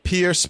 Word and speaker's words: Peirce: It's Penny, P-E-N-N-E-Y, Peirce, P-E-I-R-C-E. I am pronouncing that right, aren't I, Penny? – Yes, it's just backwards from the way you think it should be spelled.